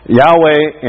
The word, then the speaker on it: Yahweh: Yahweh, and that's how we best pronounce it. We don't actually know because um, the Hebrew didn't use vowels. You know, so they, you know, uh, the actual pronunciation is is somewhat a question, and it's often translated Jehovah, and uh, and so Jehovah is one way people pronounce it.